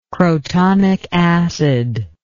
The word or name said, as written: crotonic acid